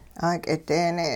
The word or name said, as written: 'aak'ideene'